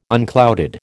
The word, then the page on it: unclouded: American English